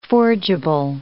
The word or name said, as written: forgeable